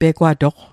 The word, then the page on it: pegwatoq: Mi'gmaq